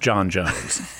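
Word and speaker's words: J'onn J'onzz: But it's pronounced John Jones.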